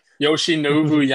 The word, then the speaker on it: Yoshinobu: Yoshinobu Yamamoto.